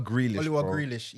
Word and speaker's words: Grealish: All right, and we have to shout out Oliver Greenish still. – Grealish is cold though. – Yeah. No. Say his name properly. Oliwa Grealish.